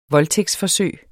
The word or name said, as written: voldtægtsforsøg